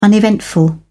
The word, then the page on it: uneventful: British English